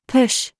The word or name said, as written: PIPE